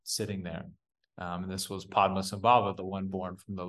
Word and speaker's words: Padmasambhava: But oh yeah, his name's Padmasambhava.